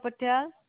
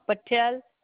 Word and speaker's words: Patel: Charulata Patel. – Can you spell that for me? – Yeah, Charulata, A as in apple, T-Tom, A apple, Charulata Patel, P as in Paul, A apple, T-Tom, E Edward, L London.